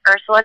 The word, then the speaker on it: Ursula: So it's Ursula Taherian, U-R-S-U-L-A, Taherian, T-A-H-E-R-I-A-N.